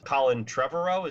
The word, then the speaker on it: Colin Trevorrow: Colin Trevorrow, is that how you say it? – Yeah.